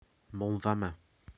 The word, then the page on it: MoelFamau: hill